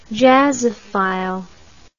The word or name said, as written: jazzophile